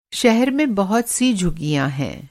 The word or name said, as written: झुग्गी